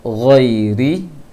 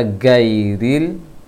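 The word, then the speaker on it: Ghairi (غير): Ghairi, jangan dibaca gairil. Gairil, orang banyak baca gairil. Gairil itu huruf G, karena pengucapan G dalam bahasa Arab tidak ada, tapi akan dicampur dengan suara tahanan angin di tenggorokan. Gho. Gho. Jangan go.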